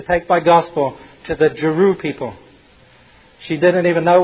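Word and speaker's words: Chru: Jeru is spelled C-H-U-R-U, but it's pronounced with a J, Jeru.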